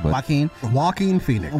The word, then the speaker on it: Joaquin Phoenix: Joaquin Phoenix.